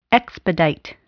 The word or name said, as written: expedite